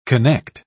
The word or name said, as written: connect